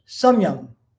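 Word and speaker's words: samyama: And if we were to distill it down into one word, we would say there's a word in Sanskrit known as samyam. S-A-M, long A, and Y-A-M-A, last A is pretty much silent.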